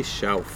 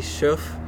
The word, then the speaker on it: Shauf: Yeah, it's spelled S H A U F.